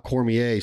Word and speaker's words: Cormier: For Randy Young, my man Scott Cormier, C-O-R-M-I-E-R, Cormier.